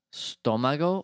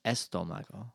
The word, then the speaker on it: estómago: Stomago or estómago? – Estómago. It has an accent in the O.